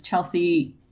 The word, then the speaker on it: Chelscey: It's at CHELSCEY, which is also why I go by a pen name, because Chelsea sounds normal, but you will never spell it right the first time around.